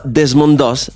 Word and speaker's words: Desmond Doss: Este, este sujeto se llama Desmond Doss.